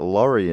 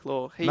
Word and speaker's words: Lorre: I always pronounce that Laurie in my head. – Oh, I also thought it was Chuck Lorre.